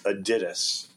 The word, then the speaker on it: Adidas: The correct German pronunciation is Adidas.